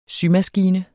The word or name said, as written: SYMASKINE